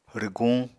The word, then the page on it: rwguñ: Apatani